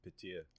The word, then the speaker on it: Petr: They say different though. They don't say Petr. They say like Petir.